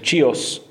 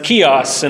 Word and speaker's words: Chios: We want to pronounce that like we would pronounce a Spanish name and say Chios, but it's Chios in the Greek.